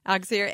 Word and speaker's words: Auxier: Auxerre. A-U-X-I-E-R.